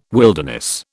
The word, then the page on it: wilderness: British English